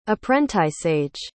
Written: apprenticeage

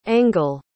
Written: engl

